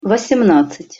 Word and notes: vosemnadcat-: Russian

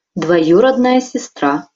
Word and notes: dvojurodnaja sestra: Russian

